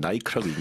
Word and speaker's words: Nike: 항상 나올 때마다 왜 미국 브랜드 나이키를 영국 사람들은 나이크라고 읽는지. – 마음대로 나이크. – 네. 자, even mainstream brands such as Nike are reducing staff members.